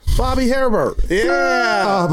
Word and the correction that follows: Hebert: That's not how you pronounce it. – For me, it does. – Bobby Hebert. Yeah. He's French.